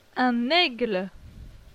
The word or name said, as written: Un aigle